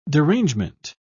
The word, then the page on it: derangement: British English